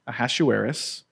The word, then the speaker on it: Ahasuerus: So the world empire that we're going to see in this Persian kingdom, and especially personified in King Ahasuerus, that's how you say his name, Ahasuerus.